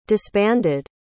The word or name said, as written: disbanded